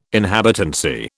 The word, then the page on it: inhabitancy: British English